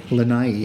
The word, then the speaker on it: Lanai: That I read an interesting, that was inspired by a place in, I hope I'm saying it right, Lanai? – Lanai. – Lanai? – Yeah.